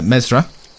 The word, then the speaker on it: Ymezra: Uh, Mesra, uh, which, by the way, is spelt Y M E Z R A.